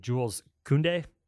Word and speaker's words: Koundé: Apparently, as of this morning, Jules Koundé, is that how you say his name? – Yeah.